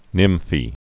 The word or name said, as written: nymphae